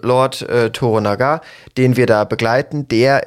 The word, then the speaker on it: Toranaga: Toranaga, ja. – Toranaga, genau. So ist es ausgesprochen, richtig.